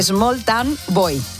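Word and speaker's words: Smalltown Boy: El primero fue ese maravilloso Small Town Bow. Small Town Bow. Hoy voy, ahí se lo digo bien. – A ver, ¿cómo era? – Small Town Boy.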